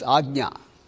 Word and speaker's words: Ajna: That's a tough one. Ajna. It's pronounced as Ajna.